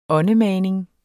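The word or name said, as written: åndemaning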